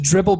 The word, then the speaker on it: Dribbble: It's just Dribbble, but it's an extra B because they couldn't get the domain for the regular B, two Bs.